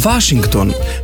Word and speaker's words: Washington: Washington bomo izgovorili Washington, München pa München.